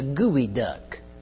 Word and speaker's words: geoduck: Another thing is called, it's spelled G-E-O-D-U-C-K. Does anybody know how to pronounce that? G-E-O-D-U-C-K. Let me pronounce it for you because you ain't going to get it. It's a gooey duck.